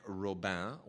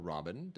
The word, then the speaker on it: Robin: And then there's also Our Daily Poison by Marie-Monique Robin, or Robin, depending on what country's pronunciation you want to use.